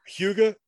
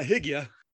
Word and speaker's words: hygge: And both of these pronunciations are by Danish speakers. Either hygge or hygge. but it's but it's not Higia.